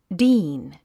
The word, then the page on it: dean: díːn; ディーン